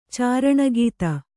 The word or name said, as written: ಚಾರಣಗೀತ